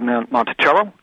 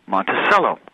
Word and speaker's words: Monticello: But every one of them pronounces it different than he pronounced the name of his place. It's called Monticello. Every place else seemed to call him Monticello.